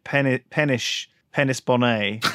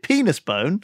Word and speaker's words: Penisboné: I'm just saying, maybe you should consider what you name your kid because, you know, Penis Bonnet is available, but it doesn't mean you should use it, you know? Because most people pronounce it penis bone.